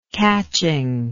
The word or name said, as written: catchingness